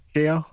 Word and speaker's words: Tchia: Next up was, I don't know how to pronounce this, Tchia? – Chia, I believe.